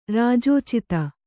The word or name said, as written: ರಾಜೋಚಿತ